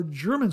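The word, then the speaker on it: Jermyn: It's spelled J-E-R-M-A-I-N-E. I believe it's pronounced German. If I'm incorrect, forgive me, but I think I heard it pronounced German somewhere online.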